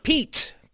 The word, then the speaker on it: Pete: Pete. Pete McMurray. – How do you spell it? – P-E-T-E.